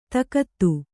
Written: ದಗದ

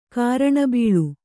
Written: ಕಾರಣಬೀಳು

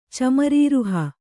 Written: ಚಮರೀರುಹ

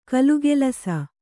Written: ಘಿಲಘಿಲಿಸು

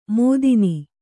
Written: ಮೋದಿನಿ

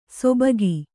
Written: ಸೊಬಗಿ

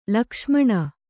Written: ಲಕ್ಷ್ಮಣ

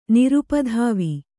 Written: ನಿರುಪಧಾವಿ

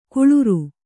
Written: ಕುಳುರು

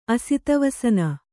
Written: ಅಸಿತವಸನ

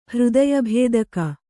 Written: ಹೃದಯಭೇದಕ

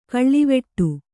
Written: ಕಾಳವಟ್ಟ

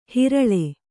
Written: ಹರಳೆ

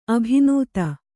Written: ಅಭಿನೂತೆ

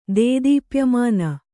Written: ದೇದೀಪ್ಯಮಾನ

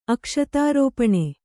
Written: ಅಕ್ಷತಾರೋಪಣೆ

